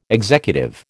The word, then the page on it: executive: American English